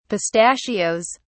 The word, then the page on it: pistachios: British English